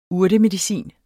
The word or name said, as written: urtemedicin